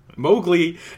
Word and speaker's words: Mowgli: Mowgli, then in parentheses, rhymes with cow glee, COW-GLEE.